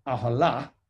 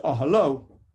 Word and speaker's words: Ahalo: It's spelt Ahala, meaning her tent, with a hay on the end, but it's pronounced Ahalo with a chola, meaning his tent.